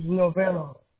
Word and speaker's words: Novello: My last name is Novello. I can spell it for you if you want. – Okay, I'm ready. – Novello. – Oh, just how it sounds. Novello. – Yes, sir. – Okay. And Jesus, you spell just like my Savior? J-E-S-U-S? – Yes, sir.